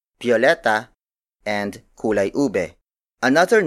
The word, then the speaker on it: biyoleta: Tagalog speakers also use the word biyoleta, biyoleta, biyoleta.